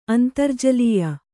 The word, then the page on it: ಅಂತರ್ಜಲೀಯ: Kannada